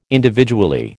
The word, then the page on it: individually: American English